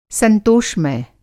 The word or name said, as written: संतोषमय